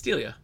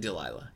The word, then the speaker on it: Delia: You're right. It's Delia. – Delia Owens. – Yeah. – A woman who, I guess I've been saying this wrong for. – You said Delilah.